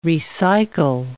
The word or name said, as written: recycle